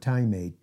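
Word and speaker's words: time (τιμή): It's the Greek word time, T-I-M-E, long E.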